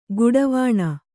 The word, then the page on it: ಘಡಾವಣೆ: Kannada